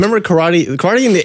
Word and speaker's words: karate: Like I think like a lot of the Eastern ones, like karate. Karate is how it's supposed to be pronounced.